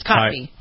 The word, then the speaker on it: Kotb: Nobody knows how to say her last name, but it's Kotb.